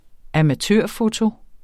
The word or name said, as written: amatørfoto